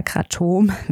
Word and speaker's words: Kratom: Die Rede ist von Kratom oder Kratom, wie es richtig ausgesprochen wird.